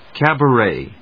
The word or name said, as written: cabaret